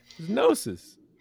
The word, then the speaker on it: Gnosis: Did you just say Genosis FUD? – How do you say it? – Gnosis. – It's Gnosis, but there's a G there. – It's a silent GIF.